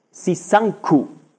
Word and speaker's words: Sheshonq: Et chez les Hittites, on trouve, et aussi chez les Assyriens, on trouve le terme Sheshonq, et chez les Assyriens, on le prononce Sisankou.